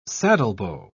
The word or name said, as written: saddlebow